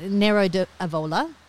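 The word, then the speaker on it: Nero d'Avola: Now we've got a bottle of wine here and it's I'm probably not going to say it right as usual, but Nero di Avola. – Yep. – That's right.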